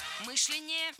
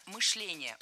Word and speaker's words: мышление: Мышление неверно. Правильно, мышление. Ударяем второй слог. Кстати, раньше допускалась двойная норма ударения в этом слове. Но сейчас вариант Сергея считается устаревшим, а литературная норма только мышление.